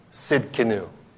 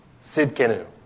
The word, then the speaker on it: Tzidkenu: It's Yahweh Tzidkenu. Yahweh Tzidkenu. Yeah, Tzidkenu, Tzidkenu. That's just the English pronunciation.